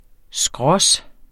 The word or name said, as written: skrås